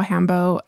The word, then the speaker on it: hanbok: I usually say hanbok because it's H-A-N-B-O-K. And so it's pronounced hanbo.